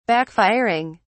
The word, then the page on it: backfiring: British English